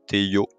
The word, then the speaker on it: Théo: Donc aujourd'hui, on se retrouve pour une nouvelle interview avec un invité de marque, Théo Maréchal, alias Théo. C'est comme ça que je le prononce. Je ne sais pas si je me corrige. – Hein. – Non, non, c'est parfait.